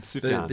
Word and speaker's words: Sufjan: The J, it's not. I was gonna say the J is silent, but it's really not silent. It's a Y. – It's a Y. Yeah. Sufjan.